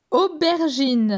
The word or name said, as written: Aubergine